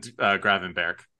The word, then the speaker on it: Gravenberch: Amrabat and Gravenberch.